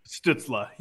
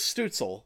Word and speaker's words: Stützle: Is that how it's pronounced, Stützle? – Stützle, yeah. – Yeah, I thought it was Stutzel, like you're going to eat a Stutzel or something like that.